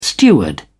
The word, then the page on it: steward: British English